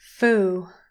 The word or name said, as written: fool